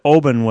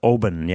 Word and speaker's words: Oban: So it's pronounced Oban? – I believe they call it Oban. Yeah. Some of these Scottish terms are not they're not the way that we might, you know, North Americans might pronounce them.